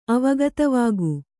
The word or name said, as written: ಅವಗತವಾಗು